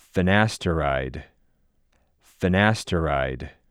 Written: Finasteride

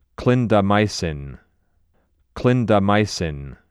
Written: Clindamycin

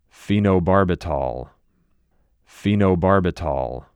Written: Phenobarbital

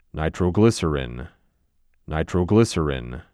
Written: Nitroglycerin

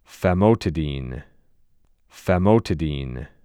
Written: Famotidine

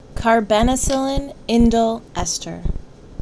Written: Carbenicillin

